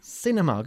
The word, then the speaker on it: cinema: Cinema, good. Y no cinema. Cinema, como si fuese una S al principio.